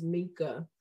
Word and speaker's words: Mika: Did I say it right? – No, her name is Mika. I'm just letting you go with them. I'm letting you go with Kima because she just said you apparently like it.